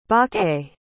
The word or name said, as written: baqer